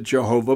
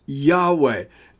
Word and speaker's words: Yahweh: And some pronounce it Jehovah, but I believe the correct pronunciation of the name of God in the Old Testament, the Tetragrammaton, the four Hebrew letter word for God, Yod-Heh-Vav-Heh, is Yahweh.